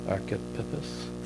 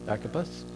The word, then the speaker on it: Archippus: What is it? Aris? No, A-R-C-H. Archippus? Sound good? We'll say that. Archippus, our fellow soldier, to the church in your house.